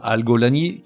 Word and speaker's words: algolagnie: Et enfin, Algolani, qui s'écrit comme ça se prononce, A-L-G-O-L-A-G-N-I-E.